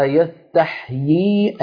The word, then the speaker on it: يستحيي: يستحيي أن يضرب مثلا ما يستحيي على وزن يستفعل يستحيي على وزن يستفعل فآخرها يا آني الياء الأولى مكسورة والياء الثانية ساكنة